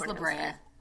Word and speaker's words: La Brea: But we do have, was it East of La Brea? – Yes. Is that how you say it? I'm not from California. Yeah, it's La Brea.